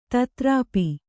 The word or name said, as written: ತದ್ರೂಪು